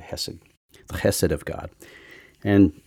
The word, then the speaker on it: Chesed: Unfortunately, the way it's spelled, people think it says cheesed because it looks like C H E S E D. Looks like cheesed or whatever. Chesed. They don't know. They don't know how to pronounce that part of it.